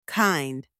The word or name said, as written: kind